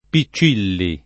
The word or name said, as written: Piccilli